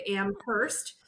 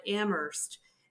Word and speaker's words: Amherst: My Southern Ontario self would say Amherst, yeah, as for the 18th century colonizer by that name. But locals call it Amherst. And so it's very different pronunciation, and it also allows us to detect immediately if somebody is not from the region, because everybody seems to be able to negotiate that hard H or lack thereof locally.